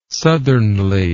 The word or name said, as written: southernly